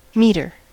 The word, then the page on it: meter: American English